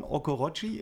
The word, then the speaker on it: Okoroji: Shima Sean Okorochi ist so richtig ausgesprochen. – Ja, wir haben es schon richtig.